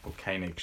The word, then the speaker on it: Koenig: So this woman called Sarah Koenig, or Koenig, she pronounces it.